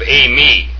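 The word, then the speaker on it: a-me (εἰμί): the epsilon iota is pronounced a, as in ace. It has a smooth breathing comma over the second vowel, and then it has an accent over the iota, and it's pronounced a-me, and it's very important to do that to yourselves, in your minds, at least in your minds or out loud.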